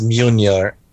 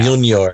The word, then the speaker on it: Mjolnir: It's it's called it's pronounced Mjolnir, yeah. Mjolnir, I think. – Yeah, yeah. M- MJ. Just forget about how it's yeah. yeah. forget about how it's spelled because pronunciation has nothing to do with spelling whatsoever.